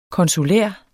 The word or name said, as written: konsulær